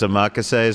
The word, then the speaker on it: omakase: I always screw up. I always say omakase, but it's omakase is the proper pronunciation.